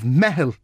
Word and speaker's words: Meitheal: With me in studios, Luke Hickson, who is a founding partner of Meitheal Design Partners.